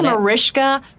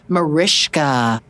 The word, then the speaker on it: Mariska: I get called Maritza, Marcika, Mariska, Markiska, M-A-R-I-S-H-H-H-H-H-H-H-H-K-A. That's how I put it on the call sheet. But it's Mariska, Mariska.